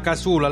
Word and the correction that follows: cassola: la, la casula, la cassola. non so come si pronuncia. La cassola. Esatto, sì, bravo, bravo quella.